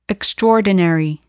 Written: extraordinary

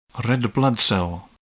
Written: red blood cell

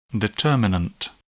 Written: determinant

